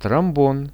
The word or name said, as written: trombon